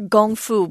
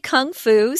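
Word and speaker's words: gong fu (功夫): It's your gong fu. But we say kung fu, so give us a break. That's what most people are used to saying. So you can go ahead and teach them the correct way to say it. Why not? Teach them what it really sounds like.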